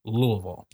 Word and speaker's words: Louisville: And he informed me that it was pronounced Louisville. You do not pronounce the E and you do not pronounce the S, Louisville.